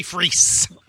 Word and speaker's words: Fris: Jimmy Frizz, F-R-I-S, and the Valiants with Serpents Freeze. – And Spiders. – I think it's pronounced Freeze. – Freeze? Thank you. – High school. I went to high school with some Freezes. I believe it's like a Dutch or whatever, but it's Freeze.